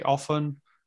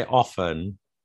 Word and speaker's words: often: Also, the pronunciation of often. – Controversial, is it? – It is controversial. What would you say? You'd say often? – Well, even though I say often, I insist on saying often.